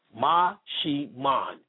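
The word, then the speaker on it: Maximón: It's spelled Maximon. Pronounced Maximan.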